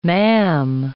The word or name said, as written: ma'am